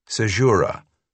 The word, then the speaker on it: Sejura: Then he taught her how to properly pronounce the town's official name. Sejura. Rhymes with tempura, he said, before telling her not to worry too much about it, since everyone just calls it the blinds.